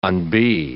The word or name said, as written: unbe